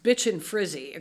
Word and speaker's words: Bichon Frise: My sister used to have one of those dogs, and it's actually pronounced Bichon Frise, according to my brother-in-law. Right, that's how you say it.